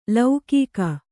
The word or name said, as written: ಲೌಕಿಕ